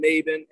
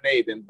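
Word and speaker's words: Mayvin: So IG is just Aaron Maven, A-A-R-O-N-M-A-Y-V-I-N, as it's spelled over here.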